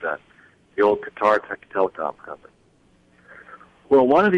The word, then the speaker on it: Qatar: There's a country in the Middle East that people pronounce it out there, gutter, but it's spelled Q-A-T-A-R.